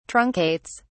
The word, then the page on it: truncates: British English